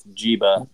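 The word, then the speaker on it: Njigba: It's N-J-I-G-B-A. – I think you may have had it right the first time.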